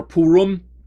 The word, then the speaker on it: Purim: Sometimes it's pronounced among American Christians as Purim or Purim, but the actual pronunciation in Hebrew is Purim.